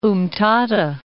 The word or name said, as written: Umtata